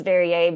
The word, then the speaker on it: Verrier: It's Verrier. – It's Verrier, but like literally everybody does it. She doesn't care. You can say it however you want. – I'll put that note in my back pocket. – It's French.